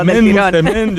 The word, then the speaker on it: msemen: Es una palabra complicada, es M-S-E-M-E-N. Msemen, ¿vale? Y se pronuncia como lo ha hecho Hakima. Pero nunca, nunca se pronuncia como lo vamos a escuchar en boca de este tiktoker, porque puede ocasionar confusiones.